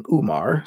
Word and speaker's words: Umar: Umar, U-M-A-R, is also sometimes spelled O-M-A-R. The first letter in Umar is actually a combination of an English O followed immediately by an Ein, which is a deep-throated ah sound that native English speakers, like me, can never pronounce well.